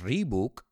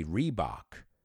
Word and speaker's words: Reebok: Y no decimos Reebok, we say Reebok.